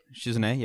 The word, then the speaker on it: Shizune: Like, Shizune. Did I say that right? – Shizune, yeah.